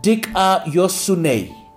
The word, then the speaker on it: dikaiosune: The root word is D-I-K-A-I-O-S-U-N-E. Dikaiosune.